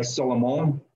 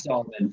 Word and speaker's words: Solomon: If we were in France, it might be like Solomon, but okay. It's Solomon for sure.